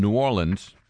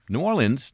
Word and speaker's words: New Orleans: And for all those people who say, well, how do you people down there really pronounce the name of that city? There were two examples right there from New Orleans. New Orleans, New Orleans, New Orleans.